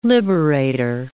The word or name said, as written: liberator